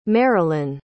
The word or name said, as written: marylyn